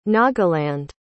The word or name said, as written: nagaland